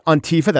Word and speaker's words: Antifa: And we'll talk about Antifa, that's how you pronounce it, with a top academic who has studied them.